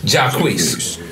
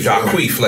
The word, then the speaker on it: Jacquees: Jacquees. – Because, you know, like, queef. Like, don't, I hate when people say Jacquees. Like, that's not right.